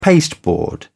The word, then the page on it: pasteboard: British English